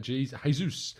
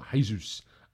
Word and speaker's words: Jesus: Jesus is pronounced the Portuguese way, not Jesus. Jesus in the Spanish way.